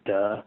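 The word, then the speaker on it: duh (德): And then the early Chinese thinkers believe that when you're in this state of wu-wei, you have this power that they, unfortunately in modern Mandarin, is pronounced duh, which it's always fine, kind of awkward, but it's I think the best translation is something like charismatic power.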